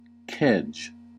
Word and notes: kedge: American English

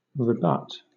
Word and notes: rebut: British English

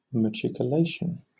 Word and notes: machicolation: British English

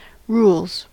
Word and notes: rules: American English

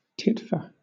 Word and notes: titfer: British English

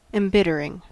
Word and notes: embittering: American English

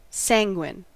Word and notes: sanguine: American English

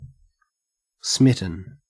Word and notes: smitten: Australian English

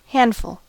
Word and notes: handful: American English

